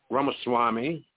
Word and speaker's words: Ramaswamy: Are you talking about Vivek Ramaswamy? – Ramaswamy. – Yeah, but no, is that how you pronounce? Yeah. – Yes.